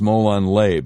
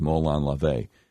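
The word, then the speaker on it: Molon LaVey (Μολὼν λαβέ): I used to think it was pronounced Molon Lay because I didn't know any better. I found out, no, it's pronounced, that's how it looks phonetically, but it's pronounced Molon LaVey, which means come and take them.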